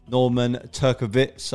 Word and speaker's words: Norman Turkowitz: Norman Turkowitz.